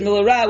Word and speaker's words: milara: the way you pronounce the word is milara with the focus on the end of the word and Gimel.